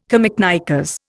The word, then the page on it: camiknickers: Indian